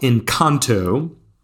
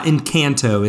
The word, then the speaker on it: Encanto: So we are talking about Encanto. – Is that the pronunciation? Is not Encanto? – It's not Encanto, it's Encanto.